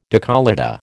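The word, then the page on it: décolleté: American English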